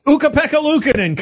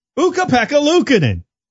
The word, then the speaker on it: Ukko-Pekka Luukkonen: Ukko-Pekka Luukkonen. Come on. – Luukkonen. – Ukko-Pekka Luukkonen.